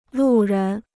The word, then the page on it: 路人: lù rén